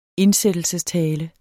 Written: indsættelsestale

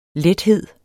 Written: lethed

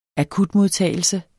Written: akutmodtagelse

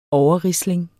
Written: overrisling

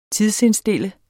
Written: tidsindstille

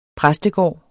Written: præstegård

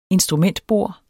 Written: instrumentbord

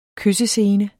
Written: kyssescene

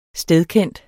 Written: stedkendt

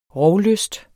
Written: rovlyst

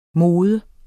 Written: mod

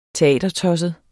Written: teatertosset

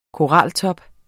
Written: koraltop

